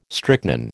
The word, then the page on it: strychnine: American English